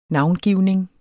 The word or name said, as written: Navngivning